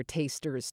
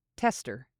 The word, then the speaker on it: taster: Remember not to pronounce this word as test. It's taste, with that long A vowel sound and an R at the end. Taster. Taster. As opposed to tester. Tester.